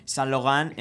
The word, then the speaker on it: Saint-Laurent: Sí, para los oyentes que no sepan mucho francés, San Logan se escribe Saint-Laurent. Lo digo así en español para quien no lo sepa pronunciar muy bien.